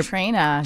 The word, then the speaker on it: Trina: I don't want to say your name wrong because I know you're Trina. – Trina. – With the letter A at the end.